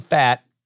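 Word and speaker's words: Pfaadt: Last night, a pitcher named Brandon Fatt, I want to say that right, it's P-F-A-A-D-T. Brandon Fatt from Louisville went to Bellarmine.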